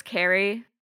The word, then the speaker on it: Cari: And he said that Kari, her name is spelled C-A-R-I, but it's Carrie.